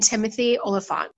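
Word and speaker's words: Timothy Olyphant: And joining the cast this season will be Rosario Dawson and Timothy Oliphant.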